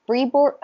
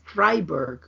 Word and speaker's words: Fribourg: This is Season 8, Episode 5, the Fribourg, I think it was the Fribourg Confidence? – They said Fribourg. – Fribourg. – It's weird. – I was mispronouncing that, but the Fribourg Confidence.